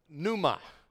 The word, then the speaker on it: pneuma: It's the Greek word pneuma. It's P-N-E-U-M-A. P-N, the P is silent. It's the Greek word pneuma.